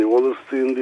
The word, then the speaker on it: Wallerstein: Fransız devrimine götürmek gibi bir ilginç Wallerstein'in galiba şeyi, evet, evet. – Yazısı. Oradan başlanabilir belki. – Hani Wallerstein diye okunuyor. Ben de bu Alman kökenli isimleri hep Almanca telaffuz etmeyi tercih ediyorum.